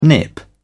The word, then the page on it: nip: British English